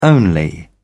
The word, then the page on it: only: British English